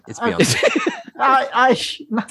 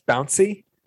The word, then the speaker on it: Beyoncé: It's Beyoncé. – Thomas, it's pronounced Bouncy.